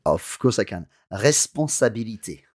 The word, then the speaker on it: responsabilité: But when you pronounce it, let's hear it. – Responsabilité. – To me, that sounds like an R-E acute.